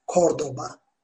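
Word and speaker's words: Córdoba: Kordoba. Bu C harfinde bunu bilmek gerekiyor arkadaşlar. Dediğim gibi ince ünlülerde F, C, kalın ünlülerde K, Ko, Ku diye okunuyor.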